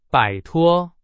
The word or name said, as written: 摆脱